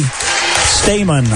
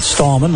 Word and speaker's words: Stamen: Abby Stamen, I guess she pronounces her name, not Stallman, like most of the Fort Recovery folks say.